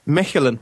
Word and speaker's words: Mechelen: Nothing to do with tires, nothing to do with big men made of tires. It's Mechelen. It's Mechelen.